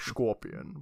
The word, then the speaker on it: Schorpion: His name was Frank Scorpion. But it was like S-C-H-O-R-P-I-O-N. So you have to say it like Sean Connery.